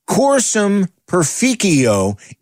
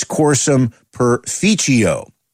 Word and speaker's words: Cursum Perficio: Find it on their new album, which is pronounced Cursum Perficio if you are using classical Latin reconstructed. However, if you are going to pronounce it in the ecclesiastical traditional Latin Italian style, it's Cursum Perficio.